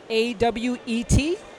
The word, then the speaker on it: Awetmoges: Or you can just Google in my name, and that is spelled AWETMOGES, Awetmoges.